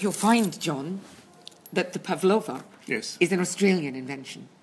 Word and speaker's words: Pavlova: So the stress always on the second syllable, Pavlova.